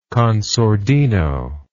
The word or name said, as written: con sordino